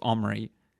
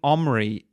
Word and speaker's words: Omri: However, the name Omri is pronounced slightly different than the English. It has a glottal stop consonant before the O. There's no English alternative to that.